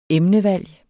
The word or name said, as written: emnevalg